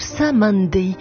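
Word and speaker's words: Cymande: Donc déjà, on vous prévient, anticipez, prononcez donc à l'anglaise le groupe Samandi,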